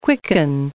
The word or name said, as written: quicken